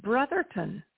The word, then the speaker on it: Brotherton: It's Brotherton.